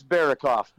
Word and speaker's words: Berokoff: You know, it's okay. I've heard it. I've heard it worse before. It's Berokoff, but that's okay.